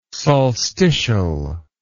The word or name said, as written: solstitial